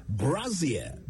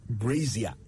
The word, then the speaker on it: brassiere: Brassiere, B-R-A-S-S-I-E-R-E, brassiere, not brazier. So many people I've heard pronounce it said brazier, but it's brassiere.